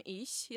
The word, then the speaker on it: ich: Jak zauważyłeś, ch w zaimku osobowym ich jest wymawiane podobnie do polskiego si. Ale nie do końca. Najlepiej poćwiczyć ten dźwięk wymawiając słowo chinina. – Chinina. – Wtedy ten dźwięk jest pomiędzy eś a ch.